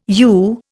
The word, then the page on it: yew: Indian